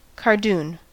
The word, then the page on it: cardoon: American English